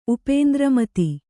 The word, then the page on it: ಉಪೇಂದ್ರಮತಿ: upēndra mati